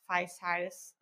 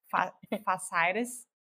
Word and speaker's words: fasciitis: And what I think is interesting on the third case, when you're describing the plantar fasciitis, oh my gosh, I have problems with this word, fasciitis. How do you say it? – Fasciitis. – Fasciitis.